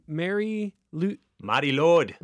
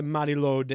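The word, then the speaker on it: Mari Lwyd: And she's lately been very focused on Welsh folklore, King Arthur, and also the Mary Lord.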